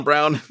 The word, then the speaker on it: Braun: You see Braun, you're like, Braun, Brown.